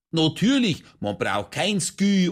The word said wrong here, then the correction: Sky: Das eine heißt nicht Skü, sondern Sky und das andere spricht man The Zone aus.